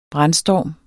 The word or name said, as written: brandstorm